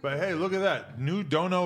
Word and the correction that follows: Duno: vlog. Duno. I'm fucking turning into Arthur saying it wrong.